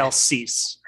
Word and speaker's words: Cease: It's spelled C-E-A-S-E, I believe. Yes. Kyle Cease.